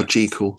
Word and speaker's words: Jekyll: Or Jekyll, I should say. Pronounce it properly. Jekyll.